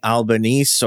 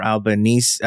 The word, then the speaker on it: Albanese: In fact, he has been replaced already in, in record uh, transition speed uh, by Anthony Albanese or Albanese, Albanese or Albanese. And the reason I'm giving all these pronunciations is because he's used all of them at one point or another over his nice. political career.